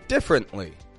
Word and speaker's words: differently: Let's consider a word that I've already said, differently. Differently. Now, I notice a lot of non-native speakers will say differently. Differently. Instead of just a quick different. Differently.